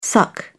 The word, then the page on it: suck: British English